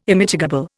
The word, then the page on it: Immitigable: Indian